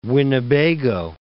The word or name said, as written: Winnebago, Lake